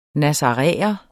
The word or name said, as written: nazaræer